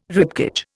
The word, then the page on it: ribcage: Indian